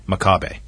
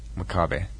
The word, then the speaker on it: Makabe: Tell me about, how do you say, Makabe or Makabe? – Makabe. – Makabe.